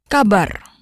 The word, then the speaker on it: kabar: Kabar. Kabar. Kabar. – Kabar. Good. Notice the R is rolled. All R's in Indonesian are rolled.